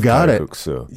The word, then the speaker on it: Kalguksu: Kalguksu.